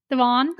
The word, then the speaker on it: Dewan: We saw that the lovely Jenna Dewan, I don't know if I'll pronounce her correctly, Jenna Dewan? – Dewan.